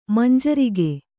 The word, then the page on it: ಮಂಜರಿಗೆ: manjarige